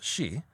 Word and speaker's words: sidhe: And when I say she, it's that word that looks like sidhe, and it's actually pronounced she.